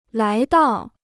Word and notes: 来到: lái dào

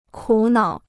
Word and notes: 苦恼: kǔ nǎo

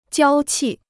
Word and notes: 娇气: jiāo qì

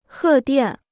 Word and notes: 贺电: hè diàn

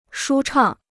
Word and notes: 舒畅: shū chàng

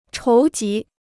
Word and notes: 筹集: chóu jí

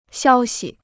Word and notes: 消息: xiāo xi

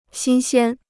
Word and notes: 新鲜: xīn xiān

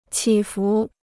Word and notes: 起伏: qǐ fú

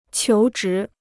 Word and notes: 求职: qiú zhí

